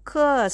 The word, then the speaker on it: 客: The word ke starting with k as a consonant is pronounced as ke and the vowel is e. Letter e is pronounced as e sound. So ke with e, ke e, ke we put the fourth tone above letter E. You pronounce it this way: k k k k k.